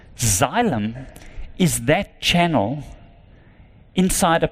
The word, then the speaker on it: Xylem: I work for an American company that's called Xylem, and you spell it X-Y-L-E-M, Xylem.